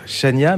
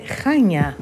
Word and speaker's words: Chania: Si on prononce à la française, je, disais, on, on, a tendance à dire Chania, mais ça c'est très mal prononcé. C'est très mal prononcé. C'est Chania.